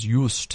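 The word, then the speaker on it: Juist: But I came across, there's a small little island, I think you pronounce it as Joost, seeing it's a German island, it's J-U-S-T.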